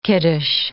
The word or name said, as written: kiddush